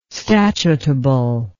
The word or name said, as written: statutable